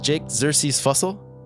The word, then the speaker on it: Jake Xerxes Fussell: I'm here with Jake Xerxes Fussell.